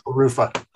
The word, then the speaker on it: LaRuffa: Welcome to the John Freakin' Muir Pod, Whitney the Ruffa. How's it going, Whitney? – Good. It's actually LaRuffa.